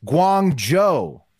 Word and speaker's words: Guangzhou: It's pronounced Guangzhou.